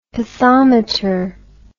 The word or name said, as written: pathometer